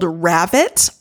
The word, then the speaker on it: Ravit: It's called Ravit, R-A-V-I-T, rhymes with rabbit.